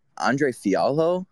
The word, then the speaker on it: Andre Fialho: Andre Fialho. I hope I'm pronouncing his name right. – Yes, sir.